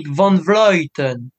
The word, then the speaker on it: van Vleuten: Je, je le prononce bien Amnik van Vleuten. – Euh, ah non non non, non non non Ah toujours pas bon. van Vleuten. – Ah, ah oui, van eh Vleuten.